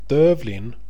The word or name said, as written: dövlin